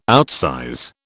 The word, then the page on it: outsize: British English